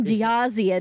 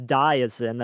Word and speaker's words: Diazen: I keep calling him Diazian, and we have to keep cutting because I say it wrong. – It looks like Diazian, but it's Diazin. That's how he said it.